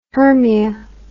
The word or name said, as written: Hermia